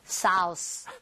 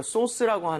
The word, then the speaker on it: sauce: AU나 AW 스펠링이 나오면 O보다 조금만 입모양을 벌려준다. 이 느낌. – 아. – 그래서 소스보다는요, 소스. 소스. 살짝만 벌리시면 되거든요.